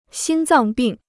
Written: 心脏病

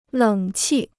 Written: 冷气

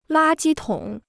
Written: 垃圾桶

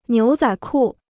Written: 牛仔裤

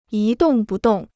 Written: 一动不动

